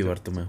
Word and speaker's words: Bartomeu: Y este, María Bartomeu, Bartomeo Sí, Bartomeu.